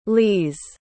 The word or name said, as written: lise